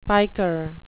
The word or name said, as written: piker